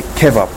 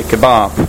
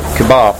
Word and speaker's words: kebab: So I don't know why I say kebab. I think it's a bad pronunciation. I should say kebab. That's what Ethan taught me about two days ago. Kebab. So the stress is on the second syllable.